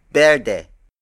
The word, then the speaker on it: berde: However, the most commonly used Tagalog word for green is berde.